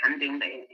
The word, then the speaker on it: Houndode: How do you pronounce your name? – Hondode. – How did I say it? Hondode? – I usually say Hondode, to be honest with you. I say Hondode in my class, but it's fine.